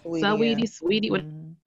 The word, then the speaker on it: Saweetie: Going along with that too, Saweetie. I guess I'm saying her name right. I don't know. – Is it Saweetie? – Saweetie. Oh, okay.